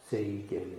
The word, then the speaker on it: Seigen: We often pronounce, they're Chinese monks, but we pronounce them in Japanese because of the Japanese Zen tradition. Seigen and Sekito. Seigen and Sekito.